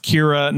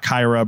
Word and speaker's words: Kira: And her name is pronounced Kira, not Kyra, because Tyra Banks demanded that the character not have a name that rhymed with her name.